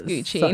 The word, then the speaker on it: Gucci: And I'm like, you're literally pronouncing your dog's name wrong. It's Gucci.